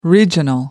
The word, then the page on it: regional: American English